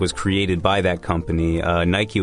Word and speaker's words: Nike: Now, 我以前以为这个读 Nike. 我很小的时候，因为按照英语的发音，我就想说 L-I-K-E 是 like, 那 N-I-K-E 就是 Nike. – Yeah. – 后来发现，哦, no, it's Nike.